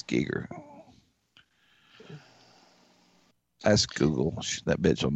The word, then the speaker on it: Giger: How you say his name? H.R. Giger or Geiger? Mm-hmm. Giger.